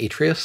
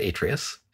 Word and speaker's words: Atreus: So Phil, who designed it, pronounces it Atreus, so we pronounce it Atreus.